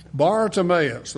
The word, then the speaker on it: Bartimaeus: As he was leaving Jericho, Bartimaeus, you got that pronunciation right on, spot on there, brother. Bartimaeus, the word bar is Aramaic for son of.